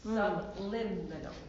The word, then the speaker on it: subliminal: Yeah, but think of it that you put the accent on limb. Subliminal.